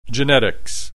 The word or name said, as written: genetics